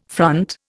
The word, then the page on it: front: Indian